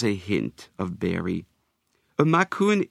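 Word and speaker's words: Macoun: Macaron can be pronounced macaron, but I prefer the French-sounding macaron.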